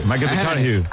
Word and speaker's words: McConaughey: Ahí, que hay que ir corrigiendo la pronunciación. McConaughey.